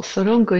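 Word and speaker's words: Zorongo: Γράφεται Ζορόγκο, αλλά προφέρεται Θορόγκο.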